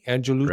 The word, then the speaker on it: Angelucci: Angelucci? – Right, Angelucci.